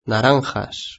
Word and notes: naranjas: Spain Spanish

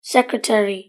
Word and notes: secretary: British English

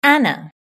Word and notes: Anna: British English

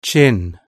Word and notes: chin: British English

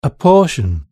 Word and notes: portion: British English

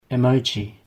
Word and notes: emoji: British English

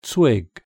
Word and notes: twig: British English

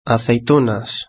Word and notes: aceitunas: Spain Spanish

